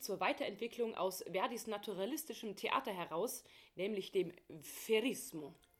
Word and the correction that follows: Verismo: Verrismo? Geht es da um Verrisse von Opern in den Zeitungen damals? Nein, ich habe das natürlich ganz falsch ausgesprochen. Verismo.